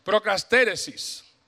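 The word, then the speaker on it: Procastéresis (προσκαρτέρησις): En el contexto bíblico, Perseverar viene del término que de hecho aparece una sola vez en todo el Nuevo Testamento, que es procastéresis. Creo que lo dije bien. Eh, pastor lingüista, sí, está bien dicho, gracias. Procastéresis.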